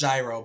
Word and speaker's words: Yairo: You know, it kind of reminds me of, you guys remember Yairo Munoz? – Yep. – Yairo Munoz. – Pronounced Gyro, but yeah.